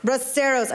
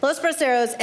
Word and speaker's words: Los Braceros: We went to Los Brazos? Braceros. I say it wrong every time, and I speak some Spanish. Anyway, Los Braceros, and I did tear up when I had the mole enchiladas.